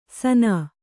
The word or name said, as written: ಸನಾ